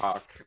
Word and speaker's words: epoch: To the Pleistocene Epic Ice Age. I don't know how it's pronounced. – Epoch, I usually think.